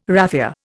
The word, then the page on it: raphia: Indian